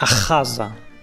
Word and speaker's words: Achaza: Achaza. Tak, Achaza powinienem wymawiać.